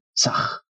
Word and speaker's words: Sah: Se exhala el aire rápidamente pronunciando el mantra Sah, el cual se pronuncia así. Sah.